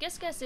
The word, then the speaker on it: Gisges: You pronounced it right, so it sounds like Gis and Guess. It stands for Give Some, Get Some. And so, what Gisges is,